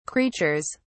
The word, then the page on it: creatures: British English